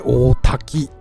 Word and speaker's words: 大滝: えー、大きく、多く、喜ぶと書いて大滝と読むんですね。